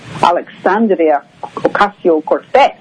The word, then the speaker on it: Alexandria Ocasio-Cortez: which is Alexandria Ocasio-Cortez, and she's the one, of course, who, did I say that right? – Yeah, you were pretty good.